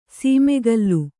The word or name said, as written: ಸಮಕಲು